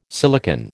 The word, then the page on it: silicon: American English